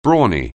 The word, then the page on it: brawny: anglais